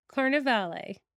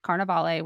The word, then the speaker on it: Carnevale: I have heard two different pronunciations of her name, the way you pronounced it, Carnevale, which is what I would believe, and then Carnevale, but I'm going with Carnevale.